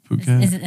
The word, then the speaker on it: Phuket: Phuket.